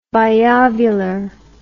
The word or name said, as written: biovular